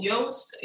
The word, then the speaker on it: Yoast: Yeah, so Yoast is spelled like Toast, but with a Y.